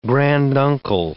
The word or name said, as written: granduncle